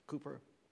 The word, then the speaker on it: Cowper: Cooper, and his name is spelled not C-O-O, it's spelled C-O-W-P-E-R, but it's pronounced Cooper.